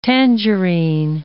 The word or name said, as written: tangerine